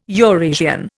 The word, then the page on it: Eurasian: Indian